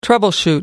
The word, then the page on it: troubleshooting: American English